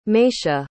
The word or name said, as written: meyshia